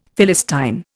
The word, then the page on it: Philistine: Indian